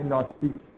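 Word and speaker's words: gnostic: ناستیک یا گنوستیک به حساب گنوسی حالا طرف تلفظ انگلیسیش میشه ناستیک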